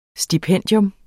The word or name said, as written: STIPENDIETS